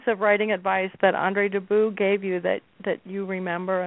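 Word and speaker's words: Dubus: his name is pronounced Debus, and what he always said was it rhymes with abuse.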